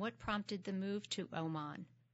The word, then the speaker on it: Oman: Is it Oman or it is Oman. Oman.